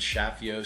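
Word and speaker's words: Chaffiot: It's Shapiro, C H A F F I O T. T is silent.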